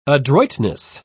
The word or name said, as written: adroitness